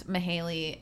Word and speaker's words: Mahalia: And Mahaley is actually spelled Mahalia, M-A-H-A-L-I-A, but pronounced Mahaley.